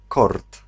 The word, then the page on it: court: France; /kuʁ/